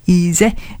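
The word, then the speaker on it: Ez'eh: Well, they don't spell it like that. E-Z apostrophe E-H. Easy.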